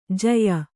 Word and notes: ಝಾಯಿ: jhāyi; Kannada

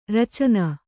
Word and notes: ರಚನ: racana

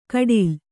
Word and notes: ಕಡಿಲ್: kaḍil

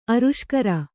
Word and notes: ಆರುಷ್ಕರ: āruṣkara

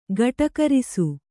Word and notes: ಗಟುಕರಿಸು: gaṭukarisu

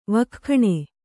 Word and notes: ವಕ್ಖಣೆ: vakkhaṇe

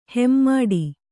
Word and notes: ಹೆಮ್ಮಾಡಿ: Kannada

